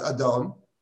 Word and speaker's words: Adonai: So it's actually very interesting that it's written Yud Hei Vav Hei, but it's pronounced Adon, which is our prayers.